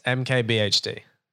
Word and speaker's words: MKBHD: I could never say his name. Is it it's a MKBHD. – Yes, MKBHD. Yeah.